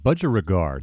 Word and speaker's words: budgerigar: Cigar and budgerigar definitely rhyme with each other.